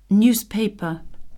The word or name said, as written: newspaper